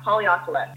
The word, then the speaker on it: Haleakala: The sunrise at Volcano Haleakala, is that how you say it? Yeah, Haleakala. And it's a national park.